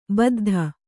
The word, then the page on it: ಬುದ್ಧ: buddha